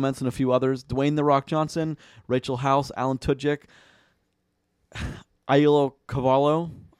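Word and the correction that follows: Auli'i Cravalho: I forgot the pronunciation of that already. I'm so sorry if I butchered that. 2016, tell us about Ollie Caravallo. – Ollie Caravallo, sorry.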